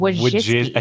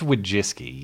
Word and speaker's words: Wojcicki: It's mm, Wojcicki. I think it's Wojcicki, yeah.